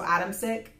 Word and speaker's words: Adamcik: It's spelled Adam C-I-K. So Adam Sick.